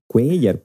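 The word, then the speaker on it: Cuéllar: O Cuellar, Cuellar, no sé cómo se dice. – Cuellar, pues si tiene tilde en la E.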